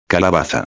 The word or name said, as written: calabaza